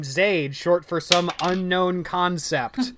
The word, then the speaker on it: Zade: Sheepishly, my mother replied, but, and this is spelled Z-A-D-E. Now, remind me, how is this pronounced? – It is Zade.